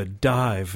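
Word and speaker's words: Dàimh: Next up, we'll listen to a band called The Dive. That's how apparently the Gaelic pronunciation is it. It's spelled D-A with an accent aigu. D-A-I-M-H. The M-H apparently is a V sound, I'm told.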